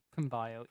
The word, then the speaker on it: Pymble: I hope I'm pronouncing that right. It's spelled P-Y-M-B-L-E. – Pine Byle, yeah.